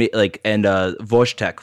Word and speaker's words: Wojtek: So in a lot of Central European languages, W's are pronounced with a V sound and J's are like a soft Y noise. So I think it'd be Vojna and Wojtek for the Boros thing.